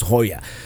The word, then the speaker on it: La Jolla: Like if you go to Southern California, La Jolla. You know, it's spelled J-O-Y-A, and it's pronounced Hoya, because you pronounce it as it would be in Spanish.